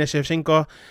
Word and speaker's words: Shevchenko: The co-main event then is the women's flyweight title, which sees Valentina Shevchenko, I felt like I said that wrong, but I didn't.